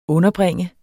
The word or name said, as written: underbringe